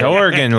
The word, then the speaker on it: Oregon: It's Oregon, like the kind you play.